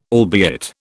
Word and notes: albeit: British English

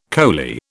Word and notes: coley: British English

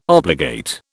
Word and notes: obligate: British English

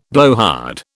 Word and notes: blowhard: British English

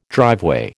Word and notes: driveway: American English

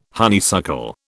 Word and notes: honeysuckle: British English

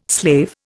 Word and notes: slave: Indian

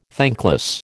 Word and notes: thankless: American English

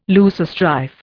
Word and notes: loosestrife: Indian

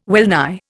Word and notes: wellnigh: Indian

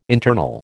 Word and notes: internal: American English

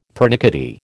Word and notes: pernickety: American English

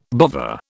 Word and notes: bovver: British English

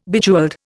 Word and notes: Bejeweled: Indian

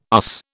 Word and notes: USS: British English

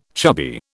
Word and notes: chubby: British English

